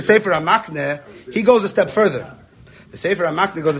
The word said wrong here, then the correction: Sefer HaMikne: a step, Sefer HaMikne really should be pronounced.